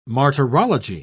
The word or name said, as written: martyrology